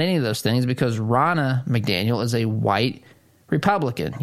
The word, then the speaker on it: Ronna: I was watching as the ABC News journalist, if you want to call her that, she introduced, as she put it, Rona McDaniel. It's Ronna McDaniel.